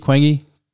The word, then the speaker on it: Quang: So my name in Vietnamese, if you say it, it's actually pronounced Quang.